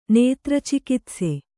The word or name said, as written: ನೇತ್ರಚಿಕಿತ್ಸೆ